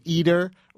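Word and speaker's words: Eder: Our next guest is the investigative reporter for the New York Times sports section, Steve Eater, rhymes with Jeter.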